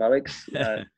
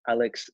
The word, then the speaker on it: Aleks: Aleks, on Twitter at Aleks Svetski, and my name is spelled Aleks, then Svetski.